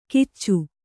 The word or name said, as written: ಕಿಚ್ಚು